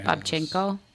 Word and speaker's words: Babchenko: Arkady Babchenko. – Babchenko. Yeah, Babchenko? Is that how you pronounce that? – Mm-hmm.